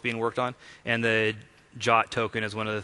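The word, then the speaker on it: JWT: All right, so now that we've talked about the on-the-wire protocol, I had mentioned that we were using the JOT or JSON Web Tokens. JOT is like the pronunciation called out in the specification.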